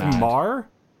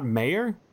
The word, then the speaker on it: Maher: Wait, is his name pronounced Maher? It's not Mayer? – Yeah, Mayer.